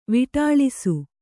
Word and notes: ವಿಢಾಳಿಸು: viḍhāḷisu

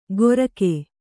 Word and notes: ಗೊರಕೆ: Kannada

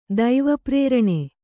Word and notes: ದೈವಪ್ರೇರಣೆ: Kannada

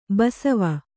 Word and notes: ಬಸವೆ: basave

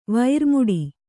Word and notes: ವೈರಮುಡಿ: vaira muḍi

